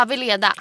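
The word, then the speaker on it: Weleda: Eh, jag använder Weldas Sensitive Care Cleansing Lotion. – Veleda? – Ja, Veleda.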